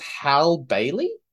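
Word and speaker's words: Halle Bailey: I think it's pronounced Hal Bailey? – Yes.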